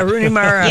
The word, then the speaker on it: Rooney Mara: Did I say that wrong? Mooney Rara. – Yeah, I knew what you were talking about. – Yeah. Rooney Mara.